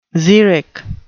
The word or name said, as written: xeric